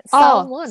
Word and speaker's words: salmon: So another so one thing is like summon. Salmon? Salmon. Yeah. – Oh, salmon. Oh, salmon. – Salmon. Yeah. I always say it salmon.